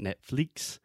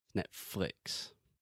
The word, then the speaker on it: Netflix: And the second thing I wanted to mention is in the second syllable, the second part of the word, we're saying flicks. So this is the I sound. It's not Netflix, it's Netflix.